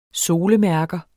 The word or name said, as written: solemærker